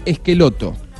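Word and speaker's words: Schelotto: Guillermo Barros se escribe Cheloto y se dice Esqueloto.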